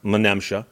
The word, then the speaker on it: Menemsha: And I did look up the word Menemsha. And strangely, the first time I saw it, I guessed it would be pronounced Menemsha. And the internet claims that I'm right in saying Menemsha.